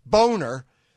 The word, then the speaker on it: Boehner: John Boehner, pronounced Boehner, the former Speaker of the House, has provisionally endorsed Paul Ryan for President of the United States.